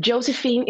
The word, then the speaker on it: Josefine: And Josefine is spelled with an F, not PH.